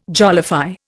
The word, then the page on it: jollify: Indian